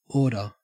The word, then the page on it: order: Australian English